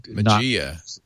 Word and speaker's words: Mageia: Yeah, it's spelled M-A-G-E-I-A. So maybe it's a Magia.